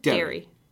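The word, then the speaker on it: Derry: It's D-E-R-R-Y. Dairy. – Dairy. – Oh, I'm saying it wrong. Like, yeah. – Yeah. No, you're saying, okay, here's a little challenge for you. – Yes. – Okay. The ice cream shop, blank queen. – Dairy. – Yeah. Okay. How's that spelled? – D-A-I-R-Y.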